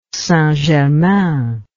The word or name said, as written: St Germain